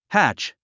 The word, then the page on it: hatch: hǽtʃ; ハチ